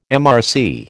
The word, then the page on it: MRC: American English